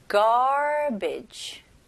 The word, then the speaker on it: garbage: 그래서 첫 음절은 gar, car 할 때 gar. 그 다음에 두 번째 음절은 bitch, bitch, garbage. garbage. 네. 강세는 첫 음절에 주시면 훨씬 더 원어민스럽게 발음하실 수 있어요.